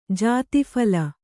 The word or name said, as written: ಜಾತೀಫಲ